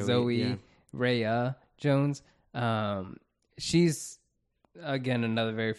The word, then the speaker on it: Rhea: Ray Jones. – Rhea Butcher. – I know. It's Rhea. I keep mispronouncing it. I've always said, for some reason, I say Ray. – Ray. – But it's Rhea.